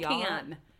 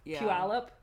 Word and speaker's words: Puyallup: Puyallup. Even though there's a K, it's Spokane. Puyallup.